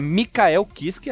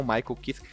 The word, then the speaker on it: Michael Kiske: Essa turnê foi assim, eles chamaram o Michael, é Mikael Kiske, a pronúncia certa, é não Michael Kiske, para cantar junto.